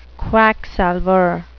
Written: quacksalver

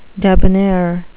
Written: debonair

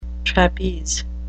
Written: trapeze

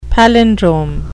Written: palindrome

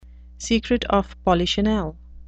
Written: secret of polichinelle

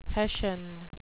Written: hessian

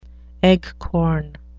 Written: eggcorn

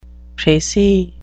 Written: precis

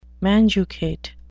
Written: manducate

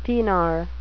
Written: thenar

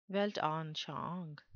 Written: weltanschauung

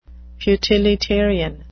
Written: futilitarian

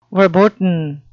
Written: verboten

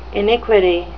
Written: iniquity

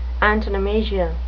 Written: antonomasia

